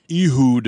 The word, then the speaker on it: Ehud: How do you say that first name? – His name is Ehud. – Ehud? – E-H-U-D. Lanido? L-A-N-I-A-D-O.